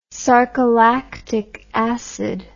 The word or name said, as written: sarcolactic acid